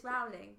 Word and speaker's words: Rowling: Rowling. – I always think it rhymes with bowling. – that would be wrong. – Rolling with the homies. – Rolling, no. – But it's Rowling.